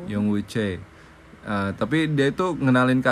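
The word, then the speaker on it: Choe (최): Kalau secara Roman bacanya Choi ya. Choi. Tapi kalau secara Hangul bacanya Choe.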